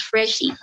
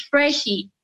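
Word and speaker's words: Phreshy: Okay, you can follow me on Instagram, Twitter, at Freshie, that's P-H-R-E-S-H-Y. Then it's a name on Onditi Freshie, guys. My name is Freshie with a P. Just like how you pronounce phone Faro. It's Freshie.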